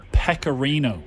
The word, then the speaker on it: pecorino: Pecorico. – Can we go to Vara on that one, please? – Pecorico. 1 second now. – I probably pronounced it wrong. – I think it's pecorino.